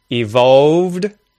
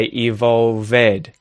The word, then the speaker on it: evolved: So they're adding in this e or e vowel sound, to which adds a syllable, so it changes the way that the word sounds for native speakers. Watched, watched, watched. Evolved, evolved. No, it's evolved.